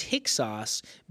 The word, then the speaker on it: Hyksos: It's spelled H-Y-K-S-O-S, Hyksos.